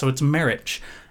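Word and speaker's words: Marić: Maric has it ends with just the letter C, but with an accent over it. And I am unfamiliar with foreign pronunciations of words. And so I didn't know what an accent over a C meant. And I guess it means the ch sound. – Ch. – So it's Maric.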